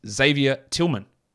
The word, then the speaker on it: Xavier Tillman: I know that's not how it's said. His name, as you can read on the screen, is Xavier Tillman.